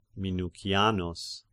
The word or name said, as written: Minucianus